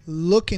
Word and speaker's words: look: Number three, look into，注意这个看，不要读作 look。 look look，不要这样读，那个中间的 o o 不读作 u，读作 o。